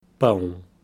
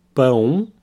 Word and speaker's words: pão: The word for bread, pão. Here we have the nasal om sound, pão pão.